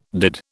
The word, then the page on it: did: British English